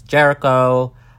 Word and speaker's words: Jerico: So for reference, my name is Jericho, J-E-R-I-C-O, so not spelled like the ancient city with an H.